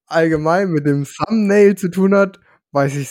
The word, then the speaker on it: Thumbnail: Aber wusstest du, das ähm, habe ich gestern auch gelernt, Thumbnail wird nicht hm, Thumbnail ausgesprochen, sondern Thumbnail. Also ohne das B, aber wird mit B geschrieben.